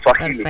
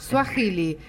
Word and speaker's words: Swahili: Como que la H se pronuncia con J, Swahili. – Perfecto. Swahili.